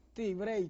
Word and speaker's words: Tibrey: Tibrey, Tibrey, sí. – Tibrey, ¿y cómo se escribe? – O con T. – T y Brey.